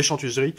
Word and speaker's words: Chantuseries: Donc les euh, tapez les éditions Les Chantuseries euh, chantuserie, comme ça se prononce, C-H-A-N-T-U-S-E-R-I-E-S, e e où il est possible de le commander sur le site.